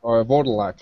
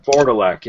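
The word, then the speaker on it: Wurdalak: How do you pronounce that vampire's name? – Vordalak. – Vordalak, yeah.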